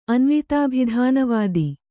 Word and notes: ಅನ್ವಿತಾಭಿಧಾನವಾದಿ: anvitābhidhānavādi

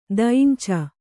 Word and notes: ದೈಂಚ: dainca

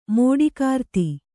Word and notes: ಮಾಟಗಾರ್ತಿ: māṭagārti

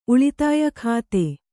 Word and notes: ಉಳಿತಾಯ ಖಾತೆ: uḷitāya khāte

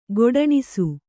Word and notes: ಗೊಡಣಿಸು: Kannada

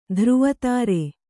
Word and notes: ಧ್ರುವತಾರೆ: Kannada

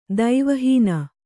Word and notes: ದೈವಹೀನ: Kannada

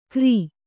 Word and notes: ಕ್ರೀ: krī

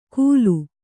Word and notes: ಕೂಲು: Kannada

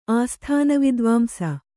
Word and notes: ಆಸ್ಥಾನವಿದ್ವಾಂಸ: āsthānavidvāmsa

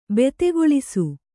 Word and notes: ಭೀತಿಗೊಳಿಸು: Kannada

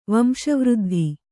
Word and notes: ವಂಶವೃದ್ಧಿ: kannada

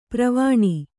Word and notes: ಪರ್ವಣಿ: parvaṇi

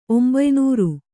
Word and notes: ಒಂಬಯ್ನೂರು: kannada

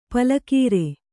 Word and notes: ಪಲಕೀರೆ: pala kīre

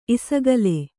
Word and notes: ಇಸಗಲೆ: isagale

